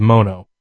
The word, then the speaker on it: Mono: I've heard some conflicting reports about the pronunciation of Mono lately, but apparently it's Spanish for monkey, so we'll go with Mono,